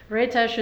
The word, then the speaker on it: Rétes: And then the series that you were doing at Ritz, is that how you pronounce it? – Rétes. – Oh, okay. I'm sorry. Thank you. – Rétes, it's a Hungarian pastry.